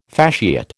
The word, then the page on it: fasciate: American English